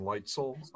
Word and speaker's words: Leitzel: My name is Dr. Galen Leitzel.